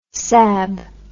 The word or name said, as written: sab